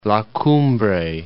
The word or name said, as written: La Cumbre